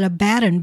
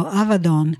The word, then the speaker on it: Abaddon: We often pronounce that Abaddon, but it's actually pronounced a V, and it is Don ending, so Avadon.